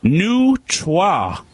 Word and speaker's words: neutrois: Neutre. – I'm sorry? – I believe that's how you pronounce it. N-E-U-T-R-O-I-S.